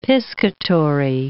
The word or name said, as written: piscatory